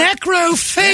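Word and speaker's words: necrófago: It rhymes with Spago, the restaurant? – Necrofago. Yeah. Of course, ghoul in Spanish.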